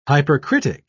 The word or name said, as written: hypercritic